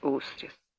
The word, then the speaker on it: Устрис: Мисс Устрис, делайте ударение на последнем слоге, пожалуйста.